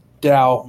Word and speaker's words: Tao: The Tau magic is actually based on, it's spelled, it's Tau magic is how it's pronounced, but it's spelled T-A-O.